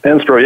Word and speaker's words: SPRO: Am I pronouncing it right, SPRO? – SPRO, yeah, you're pronouncing it right.